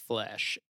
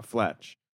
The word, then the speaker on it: Fletch: I might stick to See How They Run or Confess Flesh. Fletch? How do you say it? Confess Fletch? – Confess, yes. Comma, Fletch. – It's not flesh, okay.